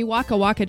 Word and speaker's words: Waka Waka: Waka Waka, or is it? Yeah, Waka Waka, that's how you say it. This time for Africa with Shakira. – Oh, that's right. That's awesome. And see, Waka Waka just makes me think of another song.